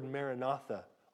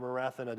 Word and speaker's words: Maranatha: And when he says here, oh, Lord, come, this Greek word Maranatha or Marathana, depending on how you want to pronounce it, depending on how it's used, it can either mean the Lord has come or the Lord will come.